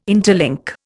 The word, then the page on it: interlink: Indian